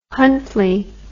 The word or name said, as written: Huntley